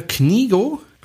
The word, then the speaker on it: Knego: Der heißt Peter Knigo.